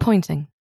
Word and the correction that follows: Ponting: Ponting, rather.